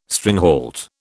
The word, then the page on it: stringhalt: British English